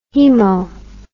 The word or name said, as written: haemo